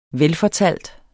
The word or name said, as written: velfortalt